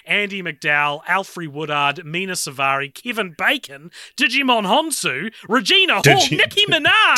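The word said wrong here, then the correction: Djimon: Is that not how you say it? – No, it's Jaimon.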